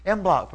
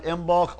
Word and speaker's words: Imbolc: Imbolc, pronounced Imbolc or Imbolc.